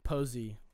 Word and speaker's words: Posey: It's spelled P-O-S-E-Y, posy, like that.